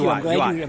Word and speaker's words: Huot: He always told me to pronounce the name Huat, like you hot. – You well, know, thank you. Huat. I'm glad you cleared that up. – H-U-O-T.